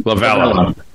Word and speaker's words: Lovullo: Or did I say his name right? Is it Lovullo? – Lovullo.